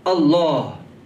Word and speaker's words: Allah: If you notice here that you never pronounce Allah, Allah. It's always Mufakham. It's always pronounced Allah.